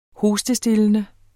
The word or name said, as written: hostestillende